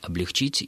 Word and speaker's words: облегчить: Конечно же, единственной правильной формой будет ударение на последний слог «облегчить», и ударение в причастиях и прилагательных «облегченный» и только «облегченный».